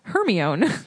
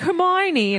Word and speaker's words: Hermione: I was pronouncing it Hermione. And then the movies came out and they were like, Hermione. And I was like, that's weird.